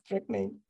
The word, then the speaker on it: Trek Mage: We didn't realize like, at that point that people don't pronounce it the correct way. Like they like look at it and like Track what? – Track Mage. Okay. Yeah. – Yes, Track Mage.